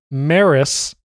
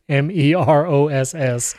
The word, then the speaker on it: Meross: And so I got the Meross, is how you correctly pronounce it, Meross.